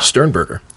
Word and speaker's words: Sternberger: Sternberger.